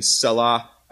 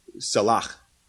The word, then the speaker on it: Salach: Uh, the first part, Muth or Methu, and then Salah. Actually, it's correctly pronounced salach. You gotta, there's, that's a Hebrew thing, right?